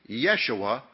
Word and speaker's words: Jeshua: Later, this name assumed the form Jeshua, sometimes pronounced Yeshua, from which came the Greek form Jesus.